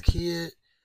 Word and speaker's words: Kidd: Like I said again, Facebook is Keelan Adair's Kid, and my Instagram is Keelan underscore Kid.